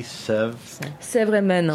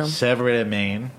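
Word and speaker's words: Sèvre-et-Maine: How do you pronounce S-E-V-R-E? – Sèvres-et-Maine. – Sèvres-et-Maine.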